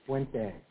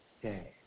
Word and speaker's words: Puente: Puente. – Okay. – Puente.